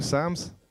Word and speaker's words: Psalm: And we're going to look at Psalm 10, or Psalms. Is that how you say it here, Psalms? Psalm. Okay, Psalm. Sorry, holy people, Psalm.